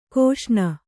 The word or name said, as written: ಘೋಸನೆ